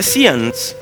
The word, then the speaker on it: seance: And they came to the woman by night and said to him, please conduct a seance for me. A seance is to go to someone. Is that a pronunciation? Seance, because it's a French word.